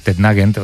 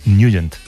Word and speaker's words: Nugent: Hombre, siempre lo hemos llamado en el barrio Tednagent o Tednagent. – Claro, ¿verdad? Yo era de Tednagent. – Pero la pronunciación correcta es Nugent.